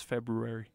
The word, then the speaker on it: February: How do you pronounce February? – February. – Did you know that you're supposed to only pronounce it February? – You're supposed to get rid of the R? – Yes.